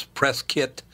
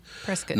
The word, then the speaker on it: Prescott: Folks here pronounce Prescott, Prescott not yep. Prescott, Prescott. Yep.